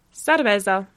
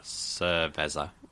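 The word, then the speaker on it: cervesa: It's similar to the Spanish, but it's cerveza. We pronounce it. Oh, is there an R in there? So it'd be like cerveza. – Yeah, sir is a C-E-R, so cerveza, yeah.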